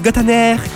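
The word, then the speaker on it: Gotainer: C'est un français, donc on pourrait dire Gottener. Gottener, ouais, t'entends le son.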